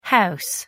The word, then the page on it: ho: American English